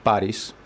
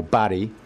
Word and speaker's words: Paris: But in Cyprus, it's fairly common, and it's pronounced Baris or Barry. – Barry. – Which sounds a bit like Barry, which, you know, takes away from the exotic.